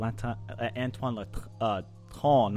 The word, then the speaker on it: Antoine Latran: It's Antoine Latran.